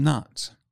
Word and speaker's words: gnat: To keep the house fresh in hot weather, windowsills washed with a weak solution of chloride of lime keeps out midges and gnats. This also helps keep the air in the house warm and fresh in the hot weather. – What's a gnat? – A gnat with a silent G is a midgie.